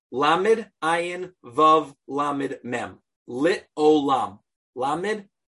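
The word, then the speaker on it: Lit Olam (לעולם): It could still be pronounced Lit Olam, because you put the vowel is still an O sound.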